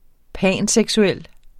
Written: panseksuel

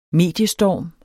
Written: mediestorm

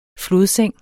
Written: flodseng

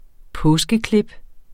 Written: påskeklip

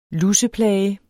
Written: luseplage

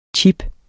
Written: CHIPPEN

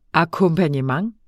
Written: akkompagnement

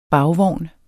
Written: bagvogn

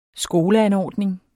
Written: skoleanordning

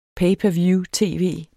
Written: per

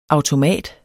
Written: AUTOMATER

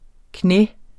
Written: knæ

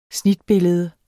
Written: snitbillede

